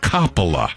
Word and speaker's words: Coppola: I found a clip of Francis Ford Coppola saying his own name, and he does, in fact, pronounce it Coppola.